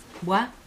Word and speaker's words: Boix: ¿Buah? – Buah. Buah. Sí. Buah, se quiere Boix. – Ah, sí. – Con B corta, con X final, Boix, y se pronuncia buah.